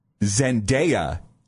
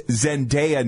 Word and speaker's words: Zendaya: Zendaya, so long A.